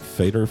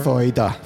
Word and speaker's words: Foeder: This is, I'm going to pronounce it Feder Fruits. F-O-E-D-E-R. – Feder.